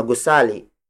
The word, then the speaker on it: gusali: For instance, mataas na gusali. Mataas na gusali. Mataas na gusali. Mataas na gusali is spelled as M-A-T-A-A-S space N-A space G-U-S-A-L-I.